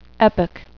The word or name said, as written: epoch